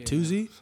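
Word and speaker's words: Toosii: It's spelled like TOOSI or like. – Toozy.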